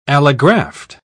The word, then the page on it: allograft: British English